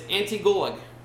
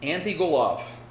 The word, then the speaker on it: Antigulov: It's Antigulog. Antigulov.